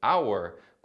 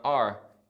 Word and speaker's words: our: Só lembrando que our é pronunciado our quando tiver mais destaque ou ênfase, sendo reduzido para are em outras situações.